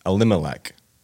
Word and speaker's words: Elimelech: The name of this man was Elimelech. Am I saying that right? – Yeah. – Okay. Elimelech.